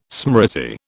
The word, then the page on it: smriti: British English